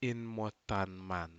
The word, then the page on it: inmo tanman: Aneityum